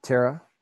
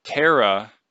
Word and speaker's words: Terah: All we know for certain is that Abram took, I'm sorry, what do you pronounce, how do you pronounce his name? – Tara. – Terah took his son Abram,